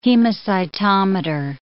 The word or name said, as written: hemacytometer